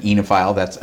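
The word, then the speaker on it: oenophile: But when I met my wife, she sort of introduced me into great food, and she was an enophile. Am I pronouncing it correctly? – Yes, that's what we are.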